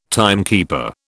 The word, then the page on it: timekeeper: British English